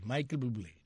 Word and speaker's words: Michael Bublé: ¿Y cómo se dice? ¿Michel Bublé o Michael Bublé? ¿tú qué sabes de eso? ¿Cómo se dice? ¿Mike Bublé? ¿Michel? ¿Michel Bublé o Michael Bublé? Ok, Michael Bublé, ya no se me olvida.